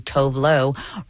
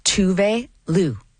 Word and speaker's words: Tove Lo: Well, turns out I have been saying Tove Lo wrong the whole time because it's actually Tove Lo.